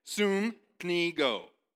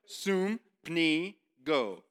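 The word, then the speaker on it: sympnigo: Luke uses the Greek word sumpino, which is a little bit different. Sumpino. Sumpino. Or sorry. Okay, let me say it again. Sumpnigo. I was saying it wrong. Sumpnigo.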